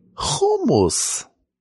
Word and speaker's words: hummus: Hummus. – Are you one of those guys that pronounces it hummus? – That's how we say it in Israel. Hummus.